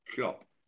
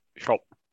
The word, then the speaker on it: Schopp: It's only one one syllable his surname, isn't it? Schopp. Schopp. you no, you shop.